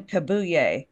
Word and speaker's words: Kabuye: His name's Stephen Kabuye.